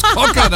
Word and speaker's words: Cocker: Von den Achtet bitte genau auf den Interpreten, wie der ausgesprochen wird. – Ja, bitteschön. In der ewigen Top 10 der Sommerhits Joe Cocker. – Cocker, sag ich schon. – Cocker natürlich. Summer in the City, was ist denn hier los, bitte?